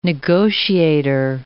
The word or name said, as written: negotiator